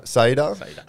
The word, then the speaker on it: Saida: Saida? – Saida.